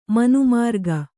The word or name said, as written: ಮನೆಮುರುಕಿ